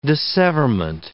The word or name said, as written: disseverment